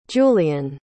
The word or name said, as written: julian